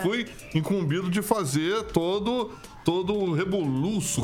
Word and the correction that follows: rebuliço: como é que fala? Rebuliço. Rebuliço. Rebuliço aí pro nosso MD de Valdinho.